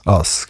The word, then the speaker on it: Us: Und momentan veröffentlichst du auf dem Zürcher Label Us, oder wie spricht man das aus? – Us, genau.